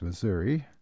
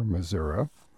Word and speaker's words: Missouri: A little over a week ago, I was out at the audio theater workshop that's held every year in West Plains, Missouri, or Missouri, or however you want to pronounce it.